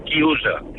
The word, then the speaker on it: chiusa: Y un momento, y quiero decirle a Fernando que en italiano se dice chiusa.